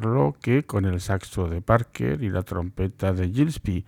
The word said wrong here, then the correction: Gillespie: a quien me he dado cuenta pronuncio a la francesa, así que diré Gillespie a partir de ahora,